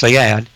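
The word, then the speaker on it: Saad: His name is Dr. Gad, G-A-D-S-A-A-D, S-A-A-D, pronounced Sayad.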